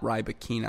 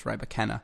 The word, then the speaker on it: Rybakina: It's not Rybakina. I believe it's Rybakina.